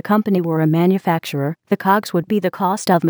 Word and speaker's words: COGS: Cost of goods sold, also known as, and pronounced, COGS, or cost of sales.